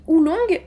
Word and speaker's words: oolong: Faut savoir qu'on dit Oulong, donc ça s'écrit O-O-L-O-N-G, euh, ou aussi wulong, W-U-L-O-N-G, qui signifie euh, dragon noir.